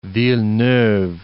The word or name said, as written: Villeneuve